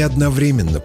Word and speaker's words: одновременно: Пора любви, пора стихов не одновременно приходят. Эти строчки поэт Нестор Кукольник написал в 1837 году. Обратите внимание, в слове «одновременно» ударение падает на третий слог.